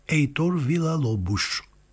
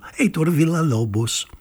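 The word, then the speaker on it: Heitor Villa-Lobos: Έιτορ Βιλαλόμπους. Έτσι θα τον προφέρανε στο Ρίο Τζανέιρο. Ενώ λίγο παραέξω, Έιτορ Βιλαλόμπους θα τον λέγανε.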